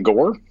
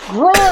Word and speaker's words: Gror: His name was Gror, and he was a mountain dwarf paladin, and she kept calling him Gore, and he would be in the background with his microphone off. He was like, it's pronounced Gror.